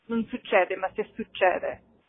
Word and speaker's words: Non succede, ma se succede: It's non succede, ma se succede, is how you would pronounce it.